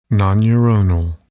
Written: nonneuronal

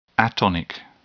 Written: atonic

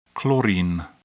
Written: chlorine

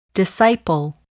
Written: disciple

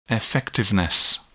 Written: effectiveness

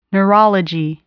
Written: neurology

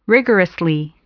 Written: rigorously

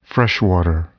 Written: freshwater

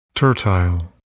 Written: tertile

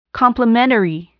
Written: complementary

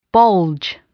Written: bulge

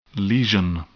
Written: lesion

